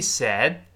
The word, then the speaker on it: said: He said，最后那个的自然的又会失去爆破了，因为爆破音后面出现其他的辅音，前面爆破音不发音了，就是做动作，但是不爆破。 Said 依然是舌尖顶住上牙的牙根儿，往后滑一点这个位置。 Said，憋一下气，再读下个词。